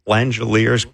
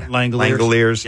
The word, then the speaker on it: Langeliers: His name should be pronounced Langelier. – That's not how it's pronounced. – Well, it should be. Langeliers. – That's not how you say it. – Langeliers. It should be Langelier. – Oh, yeah. – Mm. It should be. – Well, it's not.